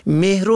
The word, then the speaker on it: مهر: و یا کلمه مهر و مهریه را که حق دختر عروسی شده است مهر و مهریه می خوانند که نادرست است. این کلمه مهر است بفتی میم، نه به کسر میم تلفظ کنیم.